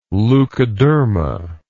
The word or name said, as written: leucoderma